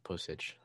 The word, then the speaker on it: Pocic: Oh, you're right. You said it right. Pocic. That's right.